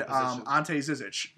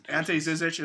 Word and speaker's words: Ante Zizic: Gershon Yabusele and Ante Zizic. Ante Zizic is a center and Gershon Yabusele is a power forward. – Are you sure you're not butchering their name? – No, that's actually correct. I swear.